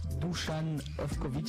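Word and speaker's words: Dušan Ivković: Avec une petite news qui concerne euh, l'équipe nationale de Serbie et Dusan Ovkovic qui devient entraîneur national. J'ai bien prononcé Ouais, ouais, ouais, si, si, ça va.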